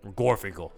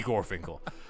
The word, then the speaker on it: Gorfinkel: Jordan B. Garfinkel. Gorfinkel, sorry, I butchered that. Jordan B. Gorfinkel.